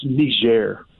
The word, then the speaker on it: Niger: That country in Africa is pronounced Niger.